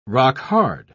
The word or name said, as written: rock-hard